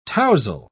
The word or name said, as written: tousle